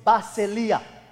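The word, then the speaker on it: basileia (βασιλεία): And the Greek word for the kingdom is basileia. That's the way I'm pronouncing it. B-A-S-E-L-I-A.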